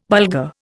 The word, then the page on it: Bulgar: Indian